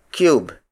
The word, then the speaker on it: kyub: The two modern Tagalog variations are cube, cube, cube, which is spelled as K-Y-U-B.